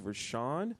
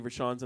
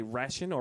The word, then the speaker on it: Rashan: So once again, this is Rashawn. Not too many Rashawns in the world. I spell my name Rashan. Most people mispronounce it. Say Rashan or Rishan. It's just Rashawn.